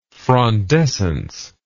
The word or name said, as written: frondescent